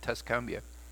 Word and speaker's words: Tuscumbia: Andy, by the way, is how you pronounce it, Tuscumbia.